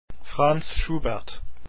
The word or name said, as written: schubert